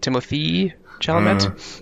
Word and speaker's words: Timothée Chalamet: Timothée, how do you pronounce it? Timothée Chalamet? – Right.